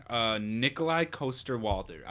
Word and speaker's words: Nikolai Coster-Waldau: So my next one is Nikolai Coster-Waldau. I think that's how you say it. – Mm-hmm. Right.